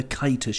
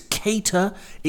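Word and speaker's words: Keïta: I will, sorry, pull you up on the kiter shout. I can't. 21-22 is a new season, and we cannot stand for it. It's cater. It's cater as in AD, Moss, or Winslet.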